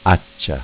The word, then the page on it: Accia: Dialetto